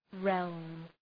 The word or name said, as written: realm